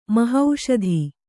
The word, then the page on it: ಮಹಾಸತಿ: mahāsati